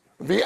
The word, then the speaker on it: Vedens: Vedens. – How do you spell it? – V-E-D-E-N-S.